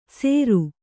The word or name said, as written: ಸೇರು